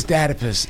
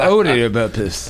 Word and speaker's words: Oedipus: I don't know that it's Oedipus. It's I mean, you don't call your mom your Ed, but it's spelled with an O. Have you ever noticed? It's called Oedipus.